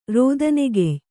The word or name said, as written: ರೋದನೆಗೆಯ್